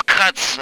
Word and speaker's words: Kuntz: Yes, that's Kuntz. Before you, even your guys make a bunch of jokes, it's Kuntz is spelled with a K. K-U-N-T-Z. Okay? – Okay.